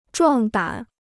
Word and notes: 壮胆: zhuàng dǎn

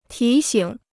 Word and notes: 提醒: tí xǐng

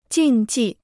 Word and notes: 禁忌: jìn jì

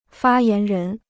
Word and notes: 发言人: fā yán rén

